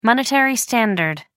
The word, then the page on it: monetary standard: American English